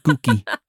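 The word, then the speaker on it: Gucci: House of Gookie. – Which apparently is how it's pronounced.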